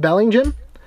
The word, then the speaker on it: Bellingham: And if I understand correctly, I've been taught this by some other fellow UK people there, that Bellingham over there is pronounced Bellingham. Bellingham. He kept saying, you guys aren't from Bellingham, you're from Bellingham. It's Bellingham.